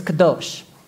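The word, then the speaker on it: kadosh (קדוש): I'm going to spell it like an Englishman. It's kadosh. It's supposed to be a K. Kadosh.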